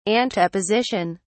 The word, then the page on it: anteposition: British English